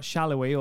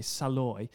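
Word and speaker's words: Salloi: You've got Shallowy, or as we say in the UK, Saloy.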